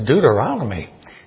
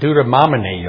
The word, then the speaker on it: Deuteronomy: One lady in our church, she said her favorite book was Deuteronomy. She didn't even pronounce it right. I think she said Deuteromini or something. Deuteromini.